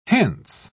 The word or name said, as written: hence